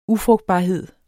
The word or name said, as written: UFRUGTBARHED